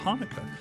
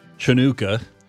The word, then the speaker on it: Hanukkah: And Gerad Hall is with us this morning, who's going to give us a little bit of information about Hanukkah. Or as a friend of mine likes to pronounce it, Chanukah.